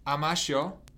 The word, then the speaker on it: Amasho: It says Amasho. – Yeah. – Is that a good pronunciation? – It is, yeah.